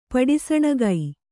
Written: ಪಡಿಸಣಗೈ

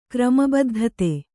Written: ಕ್ರಮಬದ್ಧತೆ

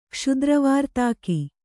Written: ಕ್ಷುದ್ರವಾರ್ತಾಕಿ

